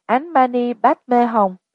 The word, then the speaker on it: Om Mani Padme Hum: Om Mani Padme Hum được đọc là Án Mani Padme Hum, sáu âm tượng trưng cho sáu cõi luân hồi.